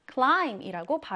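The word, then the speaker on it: climb: 자, 이때 climb에서 비는 발음을 한다, 안 한다? 안 한다, 묵음이다 였습니다. 따라서 climb가 아니라 climb이라고 발음을 해주시면 돼요.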